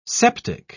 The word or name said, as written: septic